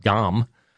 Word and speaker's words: Ngom: It's spelled N-G-O-M. The N is silent. Modu Dom.